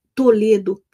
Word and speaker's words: Toledo: Está em Toledo, no Paraná. Né, tia? Vê se eu falei errado. Toledo, boa tarde, minha querida sobrinha.